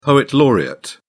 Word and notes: poet laureate: British English

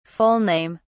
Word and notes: full name: British English

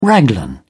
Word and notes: raglan: British English

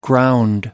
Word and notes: ground: American English

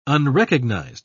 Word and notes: unrecognized: British English

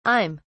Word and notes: i'm: British English

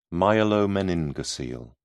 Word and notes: myelomeningocele: British English

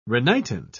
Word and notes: renitent: British English

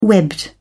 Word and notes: webbed: British English

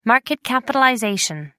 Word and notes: market capitalization: American English

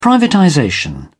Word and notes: privatization: British English

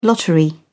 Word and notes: lottery: British English

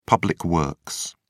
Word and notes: public works: British English

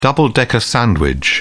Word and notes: double-decker: British English

